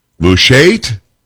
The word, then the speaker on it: Mushait: M-U-S-H-A-I-T. Mushait?